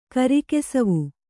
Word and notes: ಕಾರುಕಸೇವೆ: kāṛukasēve

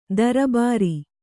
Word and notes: ದರ್ಬಾರಿ: darbāri

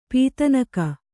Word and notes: ಪದನಖ: Kannada

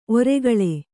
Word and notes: ಒರ್ಕಲು: orkalu

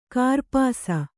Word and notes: ಕೂರ್ಪಾಸ: kannada

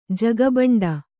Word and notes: ಜಗಬಂಡಿ: Kannada

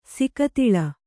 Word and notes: ಸಿಕತಿಲ: sikatila

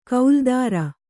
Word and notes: ಕೌಲ್ದಾರ: kauldāra

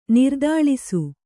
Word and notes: ನಿರ್ದಾಳಿಸು: nirdāḷisu

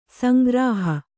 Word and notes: ಸಂಗ್ರಹ: sangraha

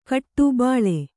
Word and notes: ಬೈಲ: kannada

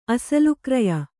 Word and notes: ಅಸಲುಕ್ರಯ: kannada

